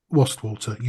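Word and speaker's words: Wastwater: Am I saying that right? – Right. Can I just interject here? – Yes. – You've, d- you've done wonderfully. Wasdale, Westwater, you've done brilliantly.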